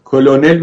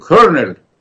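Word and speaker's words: colonel: ما به فارسی کلونل میگیم، لامش هم تلفظ کنیم، انگلیسی زبان بیقاعدی است، با ر میشه کرنل یعنی سرهنگ.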